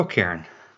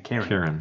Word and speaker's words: cairn: C-A-I-R-N. – That's correct. How many syllables is cairn? – One. – Yeah, one. – Why is it? When you say it, it almost sounds like you're saying Karen.